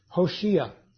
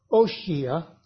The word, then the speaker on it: Hoshea: And interestingly enough, his name in the Hebrew was Hoshea or Oshia but it's usually pronounced with the letter H sound at the beginning, which is, by the way, if you recall, the name of the prophet Hosea.